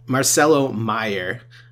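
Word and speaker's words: Marcelo Mayer: We didn't even talk about the possibility of Marcelo Meyer is how you pronounce it, my bad, Marcelo Meyer being available to the Red Sox.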